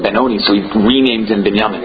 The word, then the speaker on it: Binyamin: Because the Binyamin is spelled Mila'el and not Mila'ra. So it's Binyamin instead of Binyamin. – I love Spartan. – And that's an extension of the words to maybe, like, well, I already fought in the next, which is like 2 weeks, right? – Yeah. – Okay, meaning it will be a longer gullus. – It'll be a longer gullus. Very, very good. Only. It's not a spelling difference. It's a way of pronouncing the word.